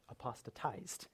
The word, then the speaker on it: apostatized: And by the way, that is the correct way to pronounce that, apostatized.